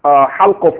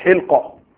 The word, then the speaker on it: halqa: Maybe you are supposed to pronounce halqa, for instance. You say what? Hilqa. And this is where you will be pronouncing it because you are reading from what? From the book, and it's not vowelized. So you may be pronouncing it that way.